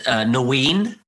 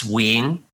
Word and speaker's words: Nguyen: So like in the UK, I said, Nguyen, but actually it's Nguyen, right? So it's completely wrong.